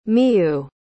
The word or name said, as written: miyu